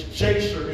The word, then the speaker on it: Jasher: Yasher. Well, it starts with a J. It's Jasher if you're going to pronounce it in English.